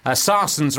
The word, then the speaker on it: Sarsons: Sarsons rhymes with Parsons, I'll give you a clue, which is like a kind of vinegar.